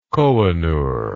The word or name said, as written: Kohinoor